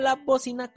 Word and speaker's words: La Bócina: Y para recordar que también puedes seguirnos en Facebook y en YouTube como La Bocina con acento en la O y con C de casa. También en Instagram y en TikTok como La Bocina Crew, C-R-E-W, por si no sabes escribirlo.